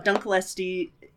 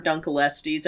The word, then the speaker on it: Dunkleosteus: Oh, Dunkleosteus or Dunkelestes. I've heard it pronounced both ways, but yeah, that one's cool.